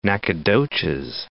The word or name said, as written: Nacogdoches